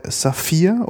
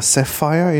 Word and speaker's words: Sapphire: Und sozusagen der klassische Bombay Sapphire oder Sapphire, je nachdem, wie man es gerne aussprechen möchte.